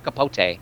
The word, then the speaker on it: Capote: It's Capote. – So in the same year, Jesus, he did Strangers with Candy, the movie, and Capote. – Mm-hmm. – My mouth keeps wanting to say Capote.